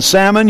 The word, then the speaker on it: salmon: You don't pronounce the L in salmon. You don't pronounce the L in almonds either.